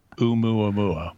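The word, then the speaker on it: Oumuamua (ʻOumuamua): You want to know about that umau mau? I think that's how you say it. Au mau mau? – Oumuamua. – Oumuamua. Oh, all right.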